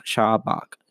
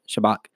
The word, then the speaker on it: Shabach: but the shout of praise is actually called Shabach, and that's pronounced Shabach, Shabach.